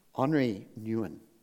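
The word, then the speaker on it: Henri Nouwen: One of the authors that she recommends is Henry Nguyen. Well, that's not how you say it. It's Henri. Henri Nguyen.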